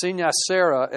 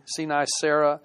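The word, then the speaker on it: sine cera: It was a stamp, and it was it's pronounced Sinicera, S-I-N-E, capital C-E-R-A, Sinicera, and it literally means without wax.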